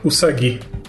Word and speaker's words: Usagi: Usagi. – Usagi? – Okay. É, a gente costuma transformar o S em Z entre duas vogais, porque a gente faz isso no Brasil, mas no Japão não tem isso não.